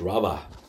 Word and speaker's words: rabbah: The word increased in the Hebrew, it's the word rabbah, pronounced rabbah, meaning to become great, become numerous before God.